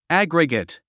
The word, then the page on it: aggregate: ǽɡriɡət; アァグリゲェット